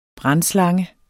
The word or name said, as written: brandslange